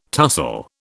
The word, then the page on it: tussle: British English